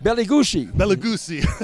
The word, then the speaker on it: Bela Lugosi: Bela Lugosi.